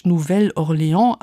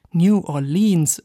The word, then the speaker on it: New Orleans: Man könnte es französisch Nouvelle Orléans aussprechen oder Standardenglisch New Orleans oder wie die Locals New Orleans.